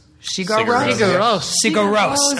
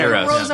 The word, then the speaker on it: Sigur Rós: So the third thing on my list is, I'm going to completely mispronounce this, the band and the song, Sigurus? – Sigur Rós. – Okay. – Sigur Rós.